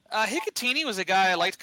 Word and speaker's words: Hikutini: Cole Hakatuni. Did I say that correctly? – I think it's Hikutini. – Hikutini.